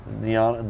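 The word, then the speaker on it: Di'Anno: Is that how you pronounce his name? – Yeah, Diano. – Diano, the original singer from Maiden.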